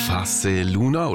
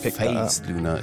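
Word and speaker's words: Phase Luna: it's not phased Luna, it's Face. – Yeah, but phase is cool, maybe even cooler.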